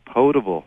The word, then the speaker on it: potable: And also, the pronunciation is potable. – Potable. – Okay. You know what? It's always bugged me, and it's potable now, and I know it.